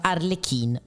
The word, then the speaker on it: Harley Quinn: Esatto, si pronuncia Arlecchin, ossia Arlecchino.